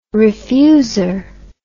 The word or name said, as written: refuser